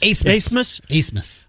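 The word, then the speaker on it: Abmas: It's not a, it's A-B-M-A-S, but it's Asemus.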